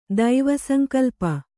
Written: ದೈವಸಂಕಲ್ಪ

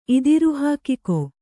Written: ಇದಿರುಹಾಕಿಕೊ

